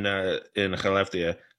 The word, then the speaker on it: Skellefteå: One in Skellefteå.